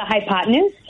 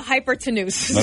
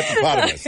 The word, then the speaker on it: hypotenuse: How did you pronounce it? This is not how I was going to pronounce it. – The hypotenuse? – Yes. I was going to say the hypertenuse. – Okay. – So that's good. – Thank you. Right. The hypotenuse. Definitely. Got it.